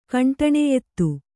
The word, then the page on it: ಖಂಡನೀಯತೆ: khaṇḍanīyate